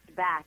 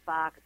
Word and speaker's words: Bach: Well, I think the first thing I like to do is think about the purpose that Dr. actually it's pronounced Bach in England, but we'll stick with Bach because that's what everybody says.